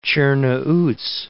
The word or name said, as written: Cernauti